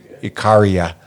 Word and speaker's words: Icaria: Uh, Costa Rica, um, and then uh, Icaraya. I don't know how to fucking pronounce it. Yeah, isolated Greek island. No, I just didn't know how to pronounce the no, I- Icaria.